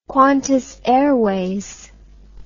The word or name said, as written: Qantas Airways